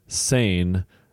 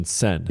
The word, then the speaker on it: Seine: And it said American pronunciation Seine, French pronunciation Sen.